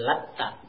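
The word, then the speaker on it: lata (اللات): Wa quri'a bitasydidiha, dan boleh juga dibaca dengan mentasydidkan ta-nya. Afara'aytumul latta, boleh seperti itu. Maknanya tentunya berbeda. Cuman kedua makna tersebut tidak maksudnya tidak bertentangan, cuman berbeda dari sebagian penafsiran. Fa'alil qira'atil ula, maka menurut pejaan yang pertama ini, lata dengan mentakhfifkan ta'nya, meringankan ta'nya, tanpa tasydid.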